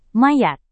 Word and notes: mayat: Indonesian

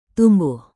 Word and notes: tumbuh: indonesian

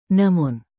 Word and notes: namun: indonesian

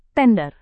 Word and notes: tender: indonesian

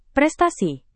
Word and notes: prestasi: Indonesian

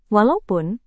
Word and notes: walaupun: Indonesian

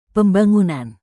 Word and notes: pembangunan: indonesian

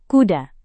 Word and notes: kuda: indonesian

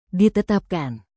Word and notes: ditetapkan: Indonesian

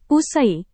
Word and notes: usai: Indonesian

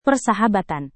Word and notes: persahabatan: Indonesian

